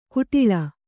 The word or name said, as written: ಕಟ್ಟಳೆ